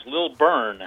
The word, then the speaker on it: Lilburn: Well, you have it almost correct, Scott. It's Lilburn. – Okay. – B-U-R-N.